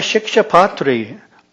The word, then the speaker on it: Shikshapatri: In the Shikshapatri, Bhagwan Swaminarayan states, practice that word for a day, rhymes with Diksha.